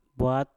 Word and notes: bwat: Mpur

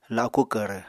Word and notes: laku kvrv: apatani

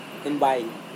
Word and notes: tembai: Serewen

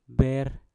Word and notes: ber: mpur

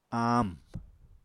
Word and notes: aamw: Apatani

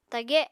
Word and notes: tage: Apatani

